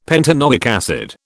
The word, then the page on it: pentanoic acid: British English